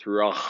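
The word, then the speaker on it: ruach (רוח): Good guttural H there, buddy. – I say, I think you got to get a little more of the Hebrew in there. It's ruach.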